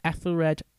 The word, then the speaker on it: Ethelred: The West Saxons, so Alfred's, heard this from the scouts, and they decided to copy the formation with Ethelred, is that how you say it? – Yeah.